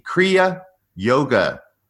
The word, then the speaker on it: Kriya Yoga: in the Kriya Yoga tradition. Did I pronounce that correctly, Philip? You did.